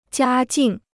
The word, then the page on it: 家境: jiā jìng